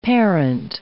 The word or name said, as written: parent